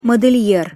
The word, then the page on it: model'er: Russian